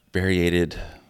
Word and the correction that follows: variegated: They have this huge blubber pouch on the bottom of their throat with this variegated blubber muscle.